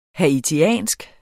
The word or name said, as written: haitisk